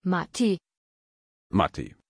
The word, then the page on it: Matti: German